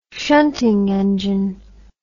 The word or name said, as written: shunting engine